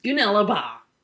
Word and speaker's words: Goonellabah: Goonellabah.